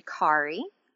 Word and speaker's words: Kari: And it's actually Kari, rhymes with Ferrari.